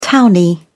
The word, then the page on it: townie: British English